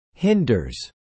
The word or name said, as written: hinders